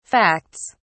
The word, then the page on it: facts: British English